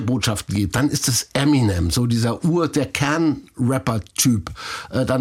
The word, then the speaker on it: Eminem: Es gibt einmal Eminem. Das war ja nichts anderes ist als die Aussprache seiner Namensinitialen M&M. Ja. Bisschen verschlurrt. Eminem.